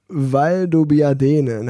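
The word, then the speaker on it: Valdobbiadene: Wie hieß das? Val de Biab. – Ja, so irgendwie. So irgendwie. Ich kann es ich kann's auch nicht aussprechen. zeig, mal, zeig mal den Zettel. Ich bin auch kein Italiener. In Valdobiadene, natürlich, ey. Valdobiadene, natürlich kennt doch jeder.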